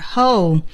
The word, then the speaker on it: whole: 这个发音呢，希望大家尽可能向英语的标准发音走，叫做 whole，而不是 whole，不是中式的，而是 whole，整个的。